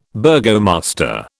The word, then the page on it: burgomaster: British English